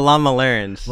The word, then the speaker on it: Lamaleran: I'm going to mispronounce them, Lamaralans? similar to my own name. – Yeah, yeah. The Lamalarans. – Lamalarans.